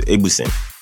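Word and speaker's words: Egbuson: The S-O-N at the end. – It's not S-I-N. – Yeah, it's pronounced S-I-N. So it's Egbusin.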